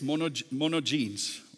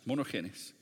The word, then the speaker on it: monogenes: That word in the Greek is the word monogenes, monogenes, almost is how we would pronounce it, monogenes.